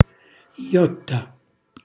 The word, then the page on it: gghjotta: Calabrese